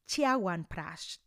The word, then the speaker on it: Chyawanprash: El segundo remedio ayurvédico es el Chyawanprash, Prash, que también se pronuncia Chiawan Prash, dependiendo de dónde viene, si es del norte de la India o del sur de la India.